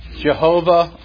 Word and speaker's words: Jehovah: Jehovah or Yahweh, either pronunciation is acceptable.